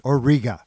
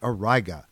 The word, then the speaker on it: Auriga: This is the alpha star in the constellation Auriga, or sometimes pronounced Auriga, which you can make out as a broad, misshapen hexagon with Capella at its top.